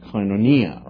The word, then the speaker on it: koinonia: koinonia, right? Koinonia, which is the correct pronunciation of that.